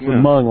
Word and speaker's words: Hmong: Yeah, it's Hmong, like mung bean, I believe.